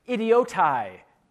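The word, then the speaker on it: Idiotai (ἰδιῶται): The second one, well, let me just pronounce for you how we think the Greek word would be pronounced. Idiotai.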